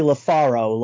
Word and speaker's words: LaFaro: It's actually LaFaro, like Laniro, LaFaro, wherever you are-o.